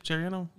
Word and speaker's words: Trayanum: Is it Trayanum? Chip Trayanum? – Yeah, Trayanum. – Trayanum, okay. I was putting too much... – Too much emphasis on the wrong syllable.